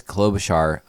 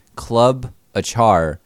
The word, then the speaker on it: Klobuchar: Amy Klobuchar is No one really knows the correct pronunciation of her last name. – Yeah, I'm pretty sure it's Klobuchar. Club I think it actually, you know what? It is two part Club a char from when she burned down that nightclub with all those people in it, mm-hmm.